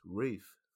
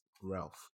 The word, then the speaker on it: Ralph: So, I discovered his name's actually pronounced Ralph Fiennes, even though it's spelled as if it's Ralph.